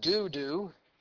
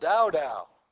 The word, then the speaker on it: Dodo: And he's called here in verse 9 the son of Dodo, or some pronounce it Dudu. I looked it up and it's actually pronounced Dow Dow.